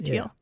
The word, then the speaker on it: Thiel: There's a recent video on YouTube by Peter Thiel. I'm not sure I'm saying his name right. T-H-I-E. – Yeah, you're saying it right. Peter Thiel.